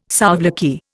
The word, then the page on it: souvlaki: Indian